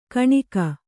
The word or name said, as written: ಕಣ್ಣಿಗೆ